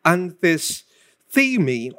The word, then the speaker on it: anthistemi: Now, the word resist comes from the Greek word anthistemi, or themi, with a long E, of course, which means to set against or oppose.